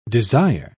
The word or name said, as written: desire